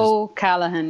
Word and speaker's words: O'Callaghan: Tanya, and I got to make sure I get your last name right because I'm just... – O'Callaghan. Yeah, everyone pronounces it slightly different. – O'Callaghan, right? – That's perfect. – Oh, okay, then that's fine. I wasn't sure if the G was silent or not, so I didn't want to butcher it.